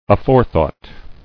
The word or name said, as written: Aforethought